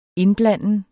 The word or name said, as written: indblanden